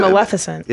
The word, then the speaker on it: Maleficent: Is that Maleficent? – A, yeah.